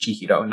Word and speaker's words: Chihiro: But when it's used in the whole name Chihiro, that's why it's pronounced Chihiro, not like Chi Sen or something like that.